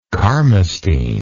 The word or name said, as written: carmustine